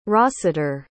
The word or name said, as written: Rossiter